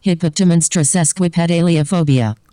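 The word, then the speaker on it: hippopotomonstrosesquipedaliophobia: And to check if I've pronounced it right, I'm about to ask GLaDOS to pronounce it. – Hippopotamonstriosesquipedaliophobia. – I think I did a better job.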